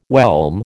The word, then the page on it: whelm: American English